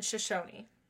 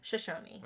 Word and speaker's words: Shoshone: K- Kootenai or Kootenai and Shoshone. I do know. I do know how to pronounce that one. – Shoshone. – Shoshone.